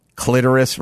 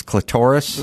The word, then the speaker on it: clitoris: As I said to my grandmother, famously, a million years ago, God knows how we got into clitoris versus clitoris. – Oh, boy. – But you're making my grandma blush. – But my grandmother, I said, clitoris, and she said, it's pronounced clitoris.